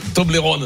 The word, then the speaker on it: Toblerone: Toblerone.